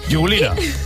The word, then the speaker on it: Jigulina: Pero bueno, la que no es de tontos es la siguiente de las canciones, que es de unos señores cuyo nombre nunca he sabido pronunciar, que son Vika Jigulina o Jiglina, no lo sé muy bien. – Jigulina.